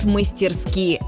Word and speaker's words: мастерски: Хоть седые его виски, он танцует мастерски. Этот шуточный стишок придумали специально для того, чтобы мы запомнили правильное ударение в слове «мастерски».